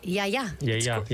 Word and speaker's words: Yiayia: Yiayia. – Yiayia.